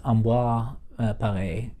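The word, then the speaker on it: Ambroise Paré: Uh, I think his name is Ambroise uh, Paré.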